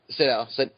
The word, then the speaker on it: Sydow: Max von Sydow? Or is it Sydow? – Sydow. – Okay, Max von Sydow.